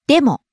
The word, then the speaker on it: でも: でも。でも。 – Starts high, falls on mo, the second syllable. – でも。